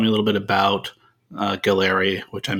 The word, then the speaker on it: Galleri: There are many that are emerging, and it is pronounced Galleri.